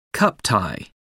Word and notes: cup tie: American English

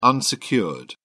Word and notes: unsecured: British English

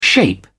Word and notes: shape up: British English

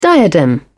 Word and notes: diadem: British English

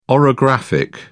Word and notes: orographic: British English

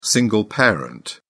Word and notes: single parent: British English

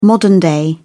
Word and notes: modern-day: British English

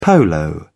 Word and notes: polo: British English

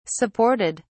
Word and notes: supported: British English

